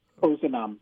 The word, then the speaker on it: Ozanam: Am I saying that right? Ozanam. – Ozanam.